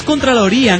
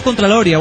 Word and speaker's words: contraloría: Es contraloría, no contraloria. Bueno, no yo importa.